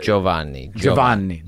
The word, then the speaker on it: Giovanni: Do you have Giovanni? – Yeah. – Like you say Joe and then Vanni.